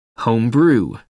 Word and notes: home brew: American English

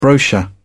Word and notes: brochure: British English

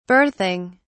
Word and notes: berthing: British English

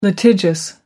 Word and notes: litigious: American English

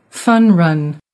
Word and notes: fun run: American English

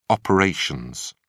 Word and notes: operations: British English

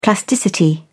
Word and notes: plasticity: British English

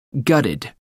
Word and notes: gutted: American English

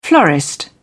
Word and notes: florist: British English

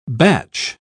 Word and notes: Bach: British English

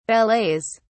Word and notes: ballets: British English

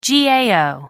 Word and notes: gao: American English

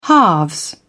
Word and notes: halves: British English

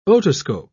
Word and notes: otoscope: British English

